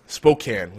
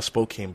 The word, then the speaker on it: Spokane: Our friends from the Sparta show are mostly from Spokane, which I always want to call Spokane, but it's actually pronounced Spokane, apparently. That's not very phonetically friendly either, because there is an E at the end there. So you would assume a hard A sound, but no.